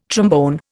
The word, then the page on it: trombone: Indian